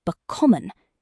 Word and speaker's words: bekommen: In German, the stress almost always falls on the first syllable of a word, unless the word has an inseparable prefix like bay, gay, air, fur, or ent. So common is common, but bekommen is bekommen.